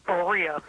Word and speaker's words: Berea: I'm going to say Berea, Ohio. Did I pronounce that correctly? – It's Berea. B is Berea.